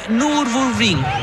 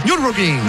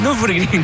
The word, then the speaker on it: Nürburgring: Ganó las 24 horas de Nürburgring. Lo pronuncié muy mal. – Nürburgring. – Nürburgring, claro.